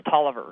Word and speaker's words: Taliaferro: It looks like Taliaferro, but it's pronounced Tolliver.